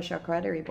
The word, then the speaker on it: charcuterie: I love a charcuterie board. How do you say that? – Charcuterie sounds more in lines of what it should be. – But who wants to eat off a cooter board? – Yeah.